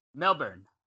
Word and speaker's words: Melbourne: Melbourne. – Yeah, that's right. – That's right. Not so like Melbourne, like Mr. Burns from The Simpsons, not Melbourne, like the Bourne Identity.